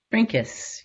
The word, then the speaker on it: Trinkaus: Trinkaus, yes. – Trinkaus. Almost like trinkets.